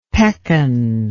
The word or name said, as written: pekan